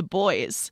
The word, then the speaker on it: Buoys: You just heard Joan Jett's 1981 banger, Bad Reputation, as well as Linda by Sydney Four Piece, The Boys, which is spelt B-U-O-Y-S.